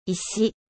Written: 石

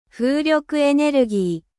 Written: energie eolienne

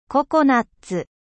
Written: noix de coco

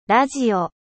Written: radio

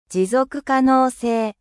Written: durabilite

